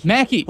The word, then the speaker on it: Mackie: Mackie. Like wacky.